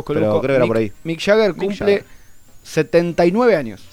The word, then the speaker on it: Mick Jagger: ¿Sabes que hoy es el cumpleaños de, Mike Jagger? ¿Mike Jagger se dice? No sé cómo se dice. Mick Jagger. – Mick Jagger.